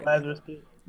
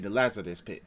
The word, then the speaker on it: Lazarus Pit: Lazarus Pit. – Thank you, the Lazarus Pit.